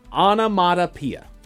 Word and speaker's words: onomatopoeia: Onomatopoeia. Onomatopoeia. – Well done. – Did I say it right? – Onomatopoeia.